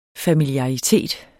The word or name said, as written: familiaritet